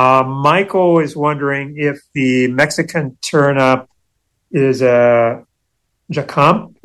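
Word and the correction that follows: jícama: Wait a minute, I'm mispronouncing it. – It's jicama.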